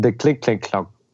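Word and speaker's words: Clignancourt: And so when I'm saying it, I pronounce it something like this, Port de Clic-Clic-Clac. And I'm... – Is it like Clic-Clac-Clac or something? – De Clic-Clic-Clac. – That's how you say it. You nailed it.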